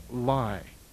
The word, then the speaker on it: Laya: On page 72 are the terms Kundalini, Yoga, and the word spelled L-A-Y-A, but pronounced Lai. This joins with the word Yoga to become Lai Yoga.